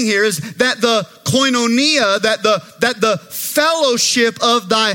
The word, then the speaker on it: koinonia: If I were to say it in Greek, I would say it like this, that the koinonia of thy faith, the koinonia of thy faith.